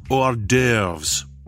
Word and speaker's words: hors d'oeuvres: And what about all the times when I need snacks and horse stuvers? – It's pronounced hors d'oeuvres.